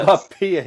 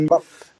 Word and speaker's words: Mbappé: Eh, ja, Mbappé, är det så? – Eller, du kör eh, uttalet Mbappé heter han. Mbappé.